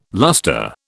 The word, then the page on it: luster: British English